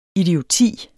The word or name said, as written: idioti